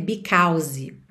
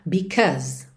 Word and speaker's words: because: E é sempre bom lembrar que because tem esse som aqui no meio, a, uh, a, uh, tá? A gente vê au, mas nada de dizer because. Uhum. E nem pronunciar o E final, que ele tá pintadinho de cinza. Então, because, because. Uhum.